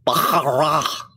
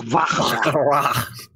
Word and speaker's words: Baccarat: I do believe it's pronounced Baccarat, right? – Yeah. – Baccarat.